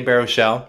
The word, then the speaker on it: Baruchel: That guy, Jay Baruchel.